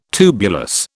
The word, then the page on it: tubulous: British English